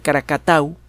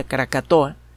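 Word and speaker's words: Krakatau: En 1883, el eh, la isla de Caracatau, mal pronunciada Caracatoa, fue destruida por una erupción volcánica ultraviolenta.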